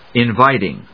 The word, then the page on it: Inviting: in・vít・ing; ‐ṭɪŋ